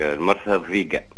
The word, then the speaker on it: المرسى الضيقة: يعيشك قولي ساعة نطقي صحيح ولا نطقك صحيح هاي فما جوستك المرسى الضيقة